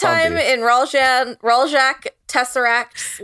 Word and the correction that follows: Tessaron: life? – No, Tessaron.